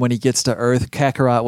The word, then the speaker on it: Kakarot: Kakarot.